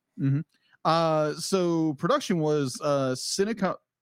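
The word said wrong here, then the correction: Syncopy: Sign copy. Okay, thank you. I was trying to figure out how you pronounce that. – It's pronounced Sign Copy.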